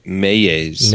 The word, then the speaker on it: Meyjes: Mayes? Mayes? – M e y j e s. May Mayes.